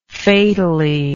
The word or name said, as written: fatally